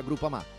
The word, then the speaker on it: Groupama: Ah no, si pronuncia Groupama.